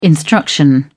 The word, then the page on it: instruction: British English